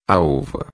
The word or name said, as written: uva